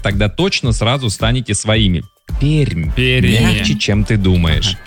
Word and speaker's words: Пермь: Да, и даже Руслан пишет, Пермь, выделяя между R и M большой мягкий знак. Как бы в официальном названии не пишется, но произносится Пермь именно. – Пермь.